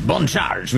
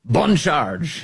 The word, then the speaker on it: Bon Charge: Bon Charge, we say with a slight French accent so I can pronounce that B-O-N perfectly. Bon Charge.